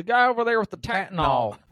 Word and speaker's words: Tattnall: So this is in Tattnall. T-A-T-T-N-A-L-L. Tattnall. – Georgia. – Definitely how it's pronounced.